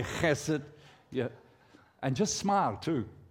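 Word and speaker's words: chesed (חסד): Agape love is what we call the Hebrew word chesed, okay? Now, I'm not spitting on you. It's how the Jewish pronounce that H sound, chesed, okay?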